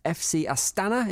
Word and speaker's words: Astana: They were at home against FC Astana. Is that how you pronounce it? – I believe so. – FC Astana? I don't think you know it with that particular accent, but it's somewhere close to it. – If you're an Astana fan, that's how you say it.